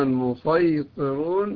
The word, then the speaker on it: المسيطرون: المقدم في الأداء في كلمة يبسط بالسين، أما المسيطرون المقدم في الأداء فيها هو الصاد.